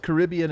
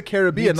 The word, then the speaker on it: Caribbean: I've never heard it pronounced Caribbean in that context. – Me either. – I've always heard it Pirates of the Caribbean. I've heard Caribbean as like Caribbean food.